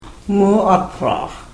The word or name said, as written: muatraax